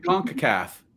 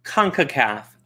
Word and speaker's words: CONCACAF: Um, hey, Concafa, Concofo, Concobama Cup. – Conca, I would CONCACAF. – CONCACAF.